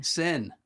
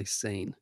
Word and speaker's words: Seine: Sen. – Mm-hmm. – Okay, Sen River Cruise in France. Sen River Cruise. I don't know why I would want to say Seine. It's spelled S-E-I-N-E.